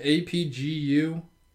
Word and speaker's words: Apgujeong: And I'm going to pronounce this terribly, oh boy. – But she attends Apujong High School. It's like APGUJEONG.